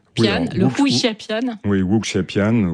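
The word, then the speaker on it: wuxiapian: Donc, je vais peut-être mal le prononcer, le Hu Xia. – Wu Xiapian.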